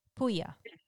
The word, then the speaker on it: Puglia: Puglia. The G is silent.